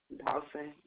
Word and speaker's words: Dawson: R-A-N-D-I-A. – And what's your last name? – Dawson. D-A-W-S-O-N.